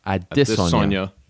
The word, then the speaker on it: Adesanya: I still say it Adesanya. – Adesanya. – Okay, yeah. There's some emphasis there in the middle that I can't quite hit.